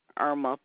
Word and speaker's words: Erma: Irma Powell. – Can you spell that first name for me? – E-R-M-A.